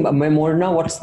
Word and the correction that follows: Maimuna: Her name is Maimuna. Maimuna. – Yeah, Maimuna. Like to rhyme with Namuna.